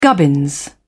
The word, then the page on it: gubbins: British English